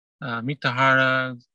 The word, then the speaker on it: Mitahara: Mitahara, if anyone wants to look it up, is spelled M-I-T-A-H-A-R-A.